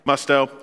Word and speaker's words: Mustoe: Last name, Musto, M-U-S-T-O-E.